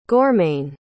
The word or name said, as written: gormain